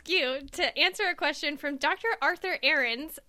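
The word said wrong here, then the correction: Aron: I have been informed that I've been saying his name incorrectly for this entire podcast. So sorry about that, Dr. Arthur Aaron.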